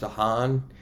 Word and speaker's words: DeHan: It's a mix between Irish and, and um, the Netherlands, and it's pronounced Dehan.